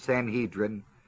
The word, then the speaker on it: Sanhedrin: Okay, it's what the word Sanhedrin means the 70. Sometimes it's pronounced Sanhedrin. Whichever way you want it, I don't care, it's still 70.